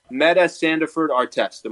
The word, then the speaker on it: Metta Sandiford-Artest: Metta Sandiford-Artest.